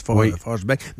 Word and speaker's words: Forsberg: Pendant tant d'années, je l'ai prononcé mal, alors que dans le fond, tout le monde le prononce Forsberg.